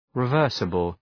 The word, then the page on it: reversible: rı’vɜ:rsəbəl